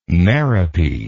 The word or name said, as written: merope